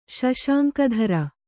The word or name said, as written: ಶಶಾಂಕಧರ